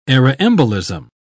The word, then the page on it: aeroembolism: British English